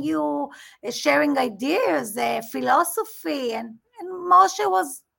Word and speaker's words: Moshe: And oh, will you say his name in Hebrew for us so people could hear Moshe.